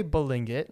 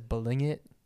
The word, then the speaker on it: Balingit: Okay, so I say Balingit. Mm-hmm. I know I've kind of Americanized it. Mm-hmm. My dad says Balingit, so like less G.